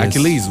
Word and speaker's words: Achilles: le 7e album du groupe avec Achille. – C'est Achilles, genre. Mais Achilles, oui, voilà.